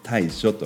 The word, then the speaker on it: 大暑: Big and hot と書いて大暑と読みます